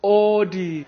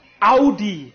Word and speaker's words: Audi: So, the first wrong car name, so many people say Audi, when it's actually pronounced Audi.